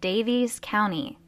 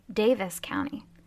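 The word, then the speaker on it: Daviess County: Hey guys, during this episode, you are going to hear me say Davies County when it's actually supposed to be Davis County. Based on the way it's spelled, you would think it would be Davies, but that is incorrect.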